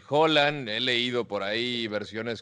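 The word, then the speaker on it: Holan: Ese Claro, Que es curioso porque se escribe H-O-L-A-N y se pronuncia Holland como si fuera una J.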